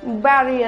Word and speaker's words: bury: No. It's berry under the earth. It's berry. B-E-R-Y. Just like cherry berry. Like that.